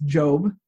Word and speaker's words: Job: His name looks like Job, but is pronounced Job. They apparently just forgot the bossy E that goes on the end, right, teachers? But Job is the name of a man who lived very early on in the Bible.